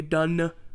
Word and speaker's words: Dunne: Like, come on, Olivia Dunne. Not nope, nope. There's a silent e there.